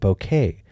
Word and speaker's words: bokeh: Or... as you're supposed to pronounce it, bokeh.